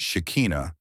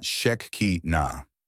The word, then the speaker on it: Shekinah: This is pronounced Shekinah and not Shekinah.